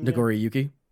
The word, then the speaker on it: Nagoriyuki: Nagoriyuki.